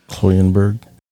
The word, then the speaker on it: Kloyenberg: One case you should be familiar with is the murder of Canadian couple Jay Cook and Tanya Von, I always say this wrong. – Kloyenberg. – Yeah, so Jay Cook and Tanya Von Kluyenberg.